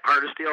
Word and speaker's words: Hardisty: Well, this has been our second edition of Catch, Cook, and Clean with Wild Goose Guides in Hardisty. Is that how you say it? – Yep, Hardisty, Alberta, Hardisty, Alberta, Canada.